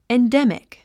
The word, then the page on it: endemic: endémik; エンデミク